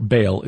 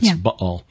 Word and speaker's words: Baal: Or Baal is the way we normally pronounce it. Scholars will put the glottal stop in there like, Baal.